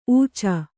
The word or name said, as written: ಉಚ್ಚ